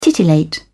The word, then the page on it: titillate: British English